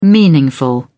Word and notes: meaningful: British English

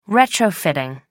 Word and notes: retrofitting: American English

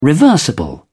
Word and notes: reversible: British English

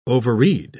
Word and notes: overread: British English

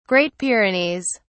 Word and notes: great pyrenees: British English

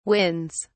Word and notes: wins: British English

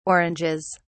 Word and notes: oranges: British English

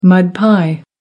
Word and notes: mud pie: American English

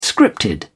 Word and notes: scripted: British English

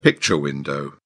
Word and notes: picture window: British English

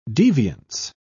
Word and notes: deviance: British English

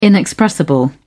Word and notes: inexpressible: British English

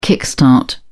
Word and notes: kick start: British English

